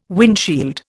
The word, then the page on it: windshield: Indian